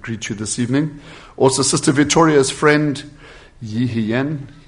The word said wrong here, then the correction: Ishen: I'm very sorry. That's Ishen. – Brother George can pronounce it for us. – Ishen.